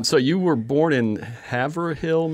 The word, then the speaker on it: Haverhill: It's like Haverhill Levine, like the musician. – Yeah, exactly. Yeah. So there's it's two syllables.